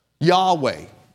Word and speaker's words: Yahweh: He was revealed by Moses to the children of Israel as Jehovah, Yahweh. That's the Hebrew pronunciation there.